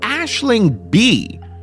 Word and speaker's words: Aisling Bea: But before Shashi, we're going to have Ashling B, who shared this next story at the Risk Live show in Los Angeles. Now, Ashling is Irish. If you look her up online, her name is spelled A-I-S-L-I-N-G-B-E-A.